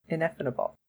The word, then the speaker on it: ineffable: And we didn't get anybody saying it was ineffable. I can't say that. Ineffable. I just added a syllable.